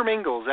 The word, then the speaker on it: Mingils: Yeah, Google my name, Peter Mingils, M-I-N-G-I-L-S. Make sure you spell it right. M-I-N-G-I-L-S.